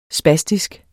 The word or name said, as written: spastisk